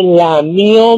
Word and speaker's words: lamió: Y dice Pepito, maestra, maestra, este niño lambió mi comida, lambió mi comida. Y le dice la maestra, no se dice lambió, se dice lamió mi comida.